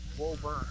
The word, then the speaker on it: Woburn: and it's pronounced Woburn. I mean, it's spelled W-O-B-U-R-N, but it's pronounced Woburn.